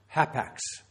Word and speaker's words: hapax: one word. H-A-P-A-X. That's how you would spell it in English spelling. Hapax. Hapax.